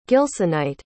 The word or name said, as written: gilsonite